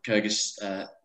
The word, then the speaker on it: Kyrgyz: The Kyrgyz state. The Kyrgyz Republic.